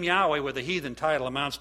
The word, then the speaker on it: Yahweh: I said, that's Yahweh. That's Yahweh.